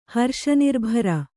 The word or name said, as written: ಹರ್ಷನಿರ್ಭರ